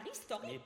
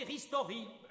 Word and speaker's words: Ristori: On m'appelait la petite Rachel, et même la Ristorique. – Mais pas que, ri suffit. Prononcez Ristori.